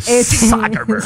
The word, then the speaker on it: Zuckerberg: Sí, a mí me daba miedo pronunciar su apellido, Sí, Zuckerberg.